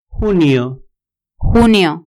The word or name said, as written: junio